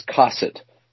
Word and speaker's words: cosset: All right, the word is cosset, C-O-S-S-E-T. It's pronounced with the emphasis on the first syllable.